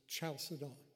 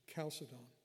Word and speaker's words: Chalcedon: The British pronunciation, I was told, in the first one is Chalcedon. Chalcedon. And the American spelling is Chalcedon.